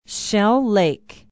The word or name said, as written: shell lake